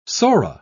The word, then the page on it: sora: British English